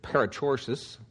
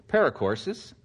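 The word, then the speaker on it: perichoresis: And one of the words that was brought up was the word, some people pronounce it parachoresis, some people paracoursis.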